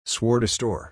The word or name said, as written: swore to store